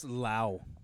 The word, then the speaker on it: Lowe: It's actually pronounced Lau.